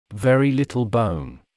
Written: very little bone

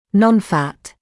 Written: non-fat

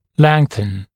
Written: lengthen

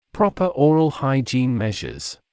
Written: proper oral hygiene measures

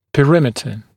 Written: perimeter

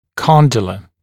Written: condylar

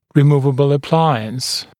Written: removable appliance

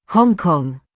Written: Hong Kong

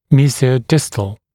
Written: mesiodistal

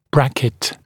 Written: bracket

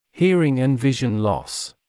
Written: hearing and vision loss

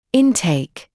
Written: intake

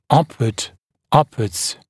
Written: upward

